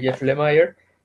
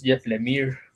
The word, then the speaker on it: Lemire: Eh, yo también originalmente lo supuse que por la fonética inglesa era Jeff Lemire, pero pues también ya sabemos que en Canadá tiene mucha influencia del, francés, del francés. Y bueno, tal que ya averigüé y es Jeff Lemire, como se pronuncia su nombre. Entonces ahí, así es como se debiera pronunciar.